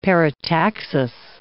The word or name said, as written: parataxis